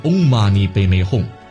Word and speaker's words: Om Mani Padme Hum: 观音心咒的发音是 Om Mani Padme Hum，也可以在后面加个观音菩萨的种子字舍，即 Om Mani Padme Hum 舍。